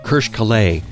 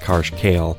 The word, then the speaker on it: Karsh Kale: Kirsch Kalay, that's right, his name is not pronounced Karsh Kale,